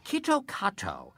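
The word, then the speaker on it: Kitto Kato (キットカット): Kitto Kato, the Japanese pronunciation of Kit Kat, sounds an awful lot like Kitokatsu, which in Japanese means you surely will win.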